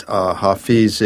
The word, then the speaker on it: Hafez: Emerson said that Hafez is the poet's poet.